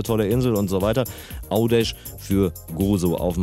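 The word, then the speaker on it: Għawdex: Dann ein W, das sich U ausspricht. Dann haben wir Au. D und E bleiben, haben wir Au, D. Folgt zum Schluss ein X. Da haben wir gelernt, das ist ein Sch. Ausgesprochen, macht aus Gavdex Audesh.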